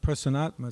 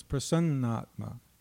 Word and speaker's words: prasannatma: It's not Prasannatma, it's Prasannatma. Prasannatma.